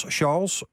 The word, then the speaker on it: Charles: Nou, Gabrielle legde in Meneer Pastoor vast hoe de 85-jarige John van Os afzwaait als pastoor en hoe de Indonesische Charles, op zijn Frans moet ik dat uitspreken, en Stefan worden klaargestoomd om het pastoorschap van John over te nemen.